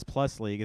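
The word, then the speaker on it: P. League+: P P-League, pronounced as Plus League, is a Taiwanese men's professional basketball league.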